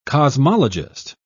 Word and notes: cosmologist: British English

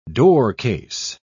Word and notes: doorcase: British English

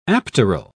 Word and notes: apteral: British English

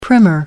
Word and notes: primer: British English

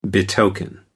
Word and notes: betoken: American English

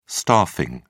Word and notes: staffing: British English

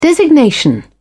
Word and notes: designation: British English

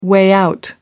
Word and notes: way-out: American English

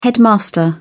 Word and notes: headmaster: British English

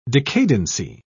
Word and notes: decadency: British English

